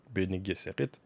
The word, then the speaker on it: Bene Gesserit: Je me dois de souligner la grande qualité de la représentation du Bene Gesserit, que j'ai peut-être encore mal prononcé. Non, je pense que c'est bien Bene Gesserit.